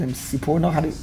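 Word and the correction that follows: Sforno: Sifurno. With a fe, not a pe. Sifurno.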